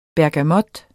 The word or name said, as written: bergamot